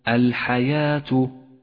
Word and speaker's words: الحياة: تأمل كلمة الحياة، الحياة، ولاحظ هذه الواو الرمادية، واعلم أنها رمادية إشارة إلى عدم نطقها، الحياة، فهي من زيادة الرسم العثماني.